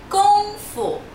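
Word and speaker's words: Kung Fu (功夫): É Kung Fu. Kung Fu. – Então não tem a pronúncia do G, né? Em português é Kung, Kung Fu. Em chinês, Kung Fu. – Isso é K-O-N-G-F-U.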